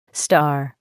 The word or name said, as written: star